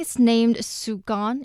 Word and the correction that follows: Shuguang: if I'm pronouncing it correctly. – In Chinese, it's Shuguang.